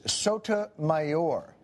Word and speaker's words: Sotomayor: The correct pronunciation is Sotomayor, unless you're a Republican, and then I believe it's pronounced Sodomizer. So now we have the pronunciation.